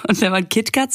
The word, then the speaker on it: KitKat: Also dieses Kit Kat, Kit Kat, wie wird es eigentlich ausgesprochen? Das ist Kit Kat.